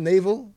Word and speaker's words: navel: Did I even say that right? – Are you thinking navy? Like Rihanna's navy? Like is it navel? N-A-V-E-L.